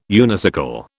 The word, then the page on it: unicycle: British English